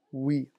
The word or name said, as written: oui